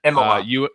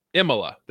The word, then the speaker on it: Imola: Imola. – Imola.